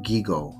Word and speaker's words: GIGO: People working with computers have an expressive term, GIGO, with hard G's. It means garbage in, garbage out.